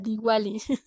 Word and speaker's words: Diwali: Ahí está el de Who de la banda y no era Duwali. Y vaya que tengo mis notas y si estaba bien escritura era Diwali.